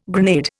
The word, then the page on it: grenade: Indian